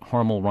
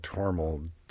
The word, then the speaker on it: Hormel: But in the 1930s, in the mid-30s, when the company started advertising on the radio, the advertisers were concerned that Hormel didn't come across clearly and that Hormel was much more understandable. So they urged the company to use the Hormel pronunciation when advertising the product. So it began, and then people got confused because some people said Hormel, and finally there was an ad which says whether you call it Hormel or Hormel, you just know you're right.